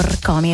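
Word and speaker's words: Rkomi: E adesso cambiamo decisamente genere, andiamo sulla musica italiana, parliamo di Rcomi, un artista, poi lo pronuncio in modo esatto perché ci tengo, Rcomi, in realtà lui si chiama Mirko, ha fatto una sorta di anagramma appunto del suo nome, come ha già spiegato in passato.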